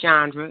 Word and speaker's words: Chaundra: My name is Chandra, and it's spelled C-H-A-U-N-D-R-A, and I am ambition.